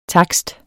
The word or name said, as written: TAKSTEN